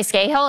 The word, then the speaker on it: Scahill: The guys from The Intercept, so Ryan Grimm and Jeremy Scahill, is that how you pronounce it? – Yes.